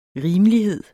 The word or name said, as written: rimelighed